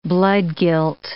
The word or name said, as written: bloodguilt